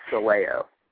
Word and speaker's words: Sileo: It's Sileo.